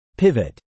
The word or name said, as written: pivot